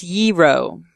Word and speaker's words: gyro: It's pronounced Yiro.